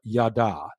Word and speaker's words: yadah: Now, the word thanks in 2 Samuel 22, 50 is the Hebrew verb yada. That's spelled Y-A-D-A-H.